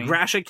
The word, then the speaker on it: Gratiot: A few weeks ago, Samsung's selfie satellite just fell in the middle of Garrett County in the United States, in the middle of a farm in Michigan. – Gratiot County.